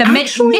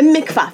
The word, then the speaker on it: mikvah: interesting that the nida time of separation in Jewish faith is completed with a special bath, and I will check pronunciation. – The mikvah. – It sounds like it happens. The mikvah. Thank you.